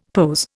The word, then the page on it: POS: Indian